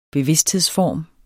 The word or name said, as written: bevidsthedsform